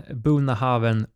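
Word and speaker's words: Bunnahabhain: Och då börjar vi med en Bonahaven Moin och eh, om man ska söka på det ska man skriva Bunna Habain. Att eh, skottarna uttalar bokstäver som inte finns.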